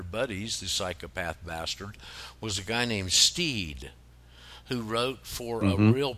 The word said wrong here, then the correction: Stead: Stead, it's pronounced Stead, just to let you know.